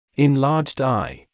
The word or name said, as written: enlarged eye